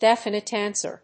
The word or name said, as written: definite answer